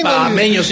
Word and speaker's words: bahameños: Entonces yo creo que aquí sí se puede pronosticar una victoria de los bahameños. ¿O sí se dice así? Pues sí, bahameños, sí.